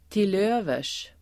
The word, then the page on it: till övers: [til'ö:vers]